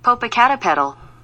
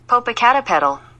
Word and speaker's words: Popocatépetl: Popocatépetl. – Yeah, that's what I said. Mexico's Popocatépetl.